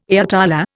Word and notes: ayatollah: Indian

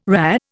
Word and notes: RHA: Indian

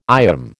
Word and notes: IOM: American English

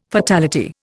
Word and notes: fatality: Indian

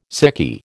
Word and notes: sickie: American English

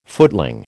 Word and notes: footling: American English

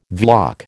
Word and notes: Vlach: American English

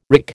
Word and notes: wrick: American English

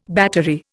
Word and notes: battery: Indian

